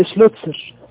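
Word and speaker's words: Schlözer: قلنا العالم يعني اسمه ايه؟ شلوتسر، شلوتسر، مع ان اسمه ما فيهوش تاء ولا كسين، اسمه بيكتب كده بالانجليزي بالحروف ال شلوتسر كده، هيك والسلامة صور كده.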